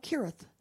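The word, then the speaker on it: Cherith: And right above the Jordan River is a brook, and it's called the Brook of Kirith. It's spelled with a C, but it's pronounced with a K.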